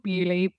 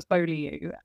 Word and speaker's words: Beaulieu: So we went out a couple of weekends ago to a place called Beaulieu, which I know that an American is going to butcher the pronunciation of, because it's spelled Baulieu. It's called Beaulieu, but I think it would probably come out as Beaulieu if you were reading it as an American.